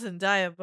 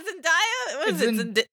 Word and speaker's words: Zendaya: You pissed off Zendaya, buddy, because I don't think this is gonna work. – Say her name right. Stop saying Zendaya. – Is it not Zendaya? – Zendaya.